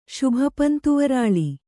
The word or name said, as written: ಶುಭಪಂತುವರಾಳಿ